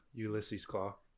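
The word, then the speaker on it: Ulysses Klaue: Ulysses Claw.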